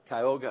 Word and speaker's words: Cayuga: And here, I asked Donna, my assistant, to look up the figures for, do you pronounce it Cayuga County? – Cayuga. – Cayuga County, okay.